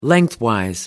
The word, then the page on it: lengthwise: British English